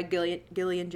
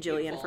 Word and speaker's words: Gillian: He has a crush on Britta, who is played by Gillian Jacobs, which I was pronouncing it Jillian for a really long time, and I apologize for saying it wrong.